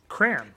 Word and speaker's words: crayon: Crayon. – He said it crayons. – It's a crayon. – It's crayon. It's two syllables.